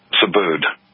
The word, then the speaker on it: Subud: Now, when you pronounce that, how did you pronounce this word, the cult? – Subud. – Okay, it's spelled S-U-B-U-D.